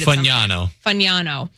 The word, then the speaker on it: Fagnano: It says Diego Pavia and Joe Fagano, the quarterback from UConn. I promise I'll get his name right. – Fagnano.